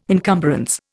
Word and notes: encumbrance: Indian

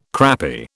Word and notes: crappy: British English

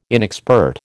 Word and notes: inexpert: American English

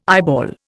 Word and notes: eyeball: Indian

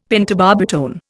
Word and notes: pentobarbitone: Indian

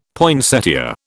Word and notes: poinsettia: British English

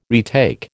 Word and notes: retake: American English

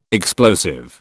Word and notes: explosive: British English

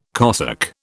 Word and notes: cosec: British English

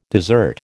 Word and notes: dessert: American English

